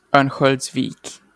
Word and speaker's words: Örnsköldsvik: Örnsköldsvik.